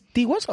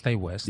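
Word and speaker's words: Ti West: ¿Es T. West o Ty West? Yo siempre he dicho Ty West. – Pues Ty West.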